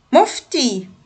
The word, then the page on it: مفتي: [muftiː]